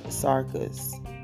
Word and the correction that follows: Syracuse: We stayed there, uh, and did I say that right? It's Syracuse.